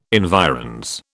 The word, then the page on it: environs: British English